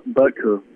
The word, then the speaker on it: Butker: He and the Chiefs kicker, place kicker, Butner, I think that's how you say it. – Yep, Butker.